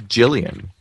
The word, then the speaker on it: Gillian: I think it's Gillian. Like a J. – Yeah, yeah. – A J sound.